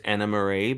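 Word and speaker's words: Annemarie: Yeah, I believe it's pronounced Anna Marie, but it's spelt like Anne Marie.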